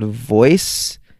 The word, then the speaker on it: Voez: Called Voice. I think that's how you're supposed to say it. It's like V-O-E-Z. – I'm sorry. Say that again? – V-O-E-Z is how it's spelled.